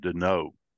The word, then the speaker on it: Denault: Yeah, he actually pronounces it Denault. I think I called him Denault forever until I ran across a guy and Bing, it occurred to me he's actually French. So it would be Denault.